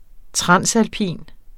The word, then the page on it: transalpin: ˈtʁɑnˀsalˌpiˀn; ˈtʁɑns-